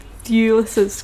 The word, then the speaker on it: Thewlis: I thought they had quite a surprising casting choice in David Lewis's character. I'm not Thewlis. – Saying this. We've just discussed it. I'm not saying this right. What? – Thewlis. – Thewlis. – Yeah. – David Thewlis's character.